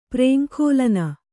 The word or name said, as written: ಪ್ರೇಂಖೋಲನ